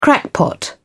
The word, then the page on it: crackpot: British English